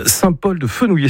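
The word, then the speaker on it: Saint-Paul-de-Fenouillet: Saint-Paul-de-Fenouillé, c'est comme ça que vous le prononcez ? – Ouais, c'est ça.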